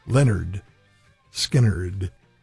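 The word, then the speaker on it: Lynyrd Skynyrd: Rock 103, Skynyrd from pronounced Leonard Skynyrd.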